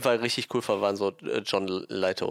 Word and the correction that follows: Lithgow: Also der, äh, ne, wie, war, wie wurde ausgesprochen? Litgo. Litgo.